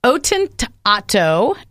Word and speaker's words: Ottantotto: and it's Ottantotto is the name. Did I say that right? Really, really close. It's 88 in Italian.